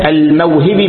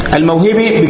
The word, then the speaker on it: الموهبي: وفضل العلم لأبي نعيم الأصبهاني ولأبي العباس أحمد بن علي بن الحارث أو الحارث الموهبي بكسر الهاء نسبة إلى كلمة الحارث والحارث، الحارث قد تكتب بدون الألف، تكتب حرف لكن تنطق حارث، فاهم؟ الموهبي بكسر الهاء نسبة إلى موهب كمجلس.